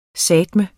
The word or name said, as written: sateme